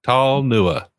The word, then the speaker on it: Talnua: Tall Nua. There you go.